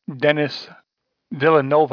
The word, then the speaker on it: Denis Villeneuve: Denis Villeneuve, I believe is how it's pronounced.